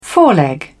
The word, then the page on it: foreleg: British English